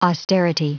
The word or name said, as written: austerity